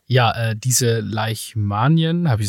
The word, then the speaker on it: Leishmanien: Leisch. Yes. Ah, also nicht wie die, nicht wie die Kröten, die Leichen. Aber mit SH, weil das anscheinend, ich glaube, weil das ein Typ namens Leichmann Leichmann erfunden hat.